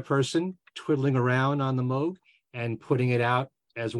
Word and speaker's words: Moog: And that is how you pronounce it, not Moog, it's Moog.